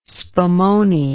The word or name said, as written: spumone